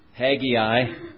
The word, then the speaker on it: Haggai: Tonight our scripture reading is in one of the minor prophets, and it's spelled H-A-G-G-A-I.